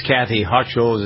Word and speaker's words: Hochul: The more I see about this Kathy Hochul, is how you pronounce her name, Lieutenant Governor.